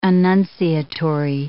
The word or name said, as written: annunciatory